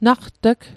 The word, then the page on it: naqt'g: Mi'gmaq